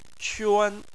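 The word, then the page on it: quan: tone 1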